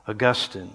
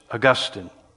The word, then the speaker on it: Augustine: Augustine is how the theologians pronounce it. Augustine.